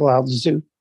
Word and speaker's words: Lao Tzu: Yeah, it's Lao Tzu.